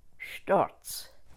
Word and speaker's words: Storz: Clara Louise Storz. S-T-O-R-Z.